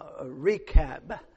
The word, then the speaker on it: Rechab: Jehu, and he is, the story's about Jehu, and he comes upon a well-known man, and his name is Jehonadab, and he is the son of Rechab, and so that's how we're going to pronounce those.